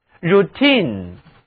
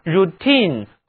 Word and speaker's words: routine: routine 重音在 t-i-n-e 这一部分上，其中的字母 i 读音是长元音 e。机关单位里的人例行公事也是这个名词，请跟我读 routine，惯例常规例行公事。